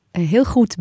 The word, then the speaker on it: Heel goed: Heel goed.